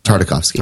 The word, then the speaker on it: Tartakovsky: Tartakovsky, however you say his name. – Tartakovsky. – Tartakovsky. Thanks.